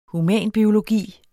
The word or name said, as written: HUMANBIOLOGI